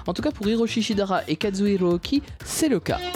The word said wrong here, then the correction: Ochi: C'est monsieur Kazuhiro Ochi d'ailleurs, excusez-moi, on va quand même le prononcer correctement à la japonaise.